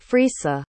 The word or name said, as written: frisa